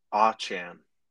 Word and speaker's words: Achane: I will say, though, quick correction. I think it's Devon Achane. – I heard Achane a bunch. I was confident at pronunciation. – So I saw a Bleacher Report came out that he came out and said he wants his name pronounced this way.